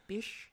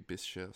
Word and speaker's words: Bysshe: It's spelled like B-Y-S-S-H-E. It's like Bish. – I feel like it'd be Bisha or something Bisha, like that.